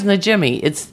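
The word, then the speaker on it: Najimy: Because her name is Najimi. You say it like it looks. It's not like some weird... – It's a weird name, but it's pronounced phonetically.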